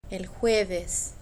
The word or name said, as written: el jueves